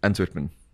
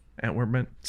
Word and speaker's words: Antwerpen: So, 7 years ago, you did your first hyperlapse in your hometown of Antwerp. Is that how you say it? – Antwerpen. – Antwerpen. – See how I roll that R there? – Yeah. – Antwerpen.